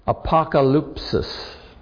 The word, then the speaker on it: apokalypsis: The word revelation, in the Greek is pronounced apokalypsis.